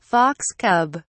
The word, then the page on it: fox cub: British English